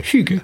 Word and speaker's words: hygge: Is it Huga? Huga. Huga. Huga. – I'm sure it's Huga. It's spelt um, H Y G G E. Huga.